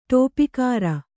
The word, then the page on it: ಡಪ್ಪುಗಾರ: ḍappugāra